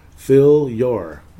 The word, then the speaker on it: Philyor: Wop. I think I'm pronouncing that right. Yeah. Uh, whoop, whoop, wop, whoop. W H O P. Philyor. Fill your. Fill your wop.